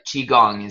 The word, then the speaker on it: Qigong: And then we've talked about this before briefly, but Qigong is how you pronounce it, right? – Uh-huh.